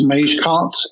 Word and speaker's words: Magecart: And this is a hacking group who are called, I think it's pronounced Magecart, M-A-G-E-C-A-R-T.